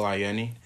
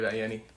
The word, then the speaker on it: Ayeni: Yep, I'm Michael Ayeni. – I'm David Ayeni.